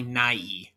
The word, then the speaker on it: Nye: Yeah, apparently I was mispronouncing it for years. It's apparently Nye.